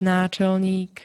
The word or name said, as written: náčelník